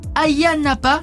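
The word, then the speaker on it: Ayia Napa: Et vous nous avez partagé que vous allez aller à Chypre, au sud-est, à Hayia Napa, je prononce bien ? – Oui. C'est ça.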